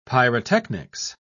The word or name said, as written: pyrotechnics